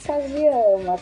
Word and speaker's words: Kajiyama: Como é que pronuncia esse nome direito? Explica pra mim, Cris. – É, Cris Kajiyama.